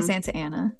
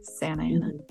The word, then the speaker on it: Santa Ana: It's my Santa Ana. Yeah. Santa Ana is one of the examples they give for the Californian accent because we don't pronounce the T's in the middle of words.